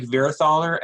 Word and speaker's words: Vierhaler: My name is Eric Vierhaler, and there have been so many people who have mispronounced my last name, Vierhaler, Vierhaler, and it's because of that.